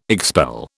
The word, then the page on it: expel: British English